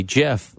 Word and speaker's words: GIF: Just when the world thought the riddle was solved, that GIF, short for graphics interchange format, should be pronounced with a soft G, GIF, like the word gentle, peanut butter giant GIF is wading into the sticky situation.